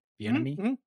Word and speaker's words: Bieniemy: And I'm saying his name correctly, correct? Right? Bieniemy? – Mm-hmm. You are.